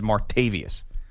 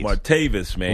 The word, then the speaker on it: Martavis: Everybody that says Martavis Bryant as Martavius. – No. – His name isn't Martavius. – Martavis, man. – Why are you adding a syllable?